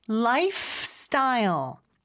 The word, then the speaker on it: lifestyle: 그 다음에 life 스타일이 아니고 lifestyle. F에서 S로 넘어가는 거. fs. 요거 많이 연습해 주시면 좋을 것 같아요.